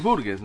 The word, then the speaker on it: Burgess: Es Burgess, me parece. – Burgess, así nomás sí. Se pronuncia.